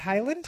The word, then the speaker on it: Haaland: Haaland.